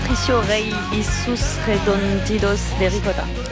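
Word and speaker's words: Patricio Rey y sus Redonditos de Ricota: Patricio Rey Jesus Redondidos de Ricota. – Oh, c'est mieux. Vous le prononcez très bien, Maureen Suignard, dans votre playlist consacrée à ce qui ressemble un peu au Johnny Argentin.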